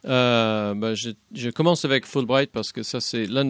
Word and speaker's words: Fulbright: Vous êtes co-président de la commission franco-américaine qui gère les programmes Fulbright.